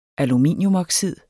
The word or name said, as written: aluminiumoxid